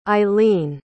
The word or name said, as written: ailin